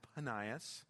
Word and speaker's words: Panias: It's the name of the city was actually called Benias, which would be pronounced Panias with a P, but I don't believe they had that letter back then.